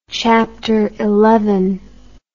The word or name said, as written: chapteral